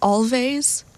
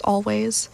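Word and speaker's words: Alvvays: Before that, Archie, Marry Me by Always, although I always think that it should be pronounced Always, like you're Swedish or something, because that's how it's spelled with two V's, but I digress, it's pronounced Always.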